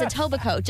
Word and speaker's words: Etobicoke: I did get called out once for pronouncing the K. – Etobicoke. – Yeah. – Nice one. – No, it's Etobicoke, just like Toronto is Toronto.